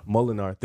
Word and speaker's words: Molinar: Number eight, Iverson Molinar. That's his name? – Molinar.